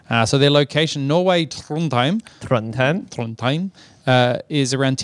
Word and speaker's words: Trondheim: Trondheim.